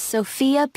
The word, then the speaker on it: Sophia: My name is Sophia Peters. Thank you. Sophia. That's S-O-F-I-A? No, it's spelled S-O-P-H-I-A. Oh, excuse me. S-O-P-H-I-A.